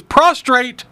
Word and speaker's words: prostrate: He was prostrate before. Did I say it wrong, didn't I? – No, you said it right.